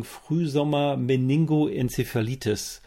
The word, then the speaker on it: Frühsommer-Meningoenzephalitis: Die ähm, oder übertragen die, äh, die, es gibt ja dann doch zumindest die, ich versuche das jetzt mal richtig auszusprechen, ich hoffe, es geht in Richtung Frühsommer Meningoenzephalitis, diese Wunderbar. Hirnhautentzündung, richtig? Genau, äh, Frühsommer-Meningoenzephalitis haben Sie gut ausgesprochen und wir sagen jetzt im Folgenden nur noch FSME, weil es uns leichter fällt ja.